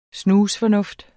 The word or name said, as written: snusfornuft